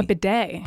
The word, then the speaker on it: bidet: I don't even know how to pronounce this word. Uh-oh. It's B-I-D-E-T. – A bidet. – Bidet.